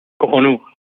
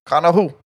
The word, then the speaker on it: Cojonu: It's like cojonu. Cojonu. It's cojonu. Like new cojos.